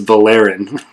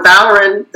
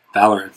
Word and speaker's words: Valorin: So Valorin, the second syllable is AR, so that's a short syllable, which means the stress goes on the first syllable. – Got it. Okay. Valorin.